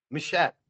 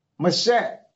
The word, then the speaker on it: Machat: Am I pronouncing your last uh, name correctly? Macot? Machat. Machat. Okay, is that French? No, it's uh, a, Samaritan, but it, it is whatever you want it to be. You pronounce it like a Scotsman.